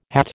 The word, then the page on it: hat: American English